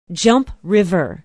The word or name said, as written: jump river